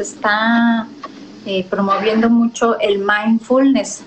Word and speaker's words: mindfulness: Se pronuncia mind como de mente. – Mente. – fullness, así como se escucha. pero junto, la palabra va junta, mindfulness.